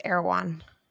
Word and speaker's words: Erewhon: W-H-O-N. Yeah. – That's not how you pronounce it. It's Erewhon.